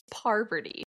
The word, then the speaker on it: Parvati: It's Parvati.